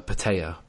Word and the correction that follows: Protea: I think, I'm pretty sure it's Protea. Protea. Yeah. Ah. Uh. That's just how they say it.